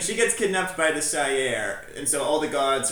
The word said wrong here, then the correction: Shi'ar: How do you say it? – Asgard? – No, Shire. – Shire? – I say Shi'ar.